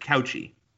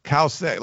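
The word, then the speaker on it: Cauce: Is it Kause or is it Couchy? – Kause. Like I've looked up her pronunciation. They say cow, C-O-W, dash S-A-Y. And it's C-A-U-C-E. So that's there's complications in all three parts of her name. So that's interesting. But yeah, at least the pronunciation I looked up, it was Kause.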